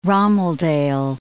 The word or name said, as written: romeldale